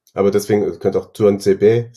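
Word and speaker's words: Tuanzebe: Juan Sebe, wird das so ausgesprochen? – Genau. Ich habe mir ein Twitter-Video von Aston Villa mal angeguckt. Das T vorne spricht der selber wie so ein Juan Sebe.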